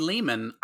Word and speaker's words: Lehman: 1981, Lou Lumen's The Pit. – Is that how you pronounce his last name? It's not Lou Lehman? – Probably Lehman. I read it wrong.